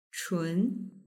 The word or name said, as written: chun